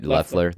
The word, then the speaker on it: Loeffler: and Loeffler, right? Loeffler.